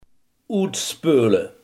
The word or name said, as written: uitspoelen